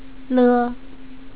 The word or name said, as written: le